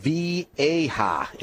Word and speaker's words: Viaje: We are going to smoke Viaje, V-I-A-G-E, Viaje is how it's pronounced.